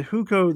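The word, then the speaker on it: Hukou (户口): So is the hukou the fact? Okay, so if I have an urban hukou, am I saying that right? Hukou. – Yeah.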